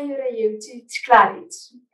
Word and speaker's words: Jurajevčič Klarić: It's Anna Jurajevčič Klarić. Actually, I have two last names, but they're both very hard to say in Slovenian too.